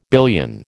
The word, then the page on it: billion: American English